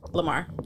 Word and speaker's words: Lamar: Francesca Amari? – Lamar.